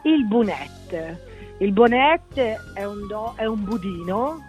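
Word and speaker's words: bonet: ditela quella T, non dite bunè, perché bunè non ve lo danno.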